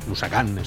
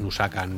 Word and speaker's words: Nusakan: Jaime Núñez, director creativo de Nusakan, Nusakan Studios, que nos lo ha dicho antes, dice poner el acento en la A, que es japonés, no es Nusakan ni, ni Nusak, nada, Nusakan Studios.